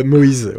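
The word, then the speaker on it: Moyes: Euh, Moïse, oui.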